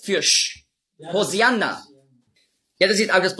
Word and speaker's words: Hosianna: Hosianna ist einfach die griechische Aussprache von Hoshi'anna. Hosianna, das ist die griechische Aussprache. Weil die Griechen, die haben im Alphabet kein Sch-Laut. Nur Z. Die Hebräer schon. Auf Hebräisch sagen Hoshi'anna. Aber Griechen können das nicht.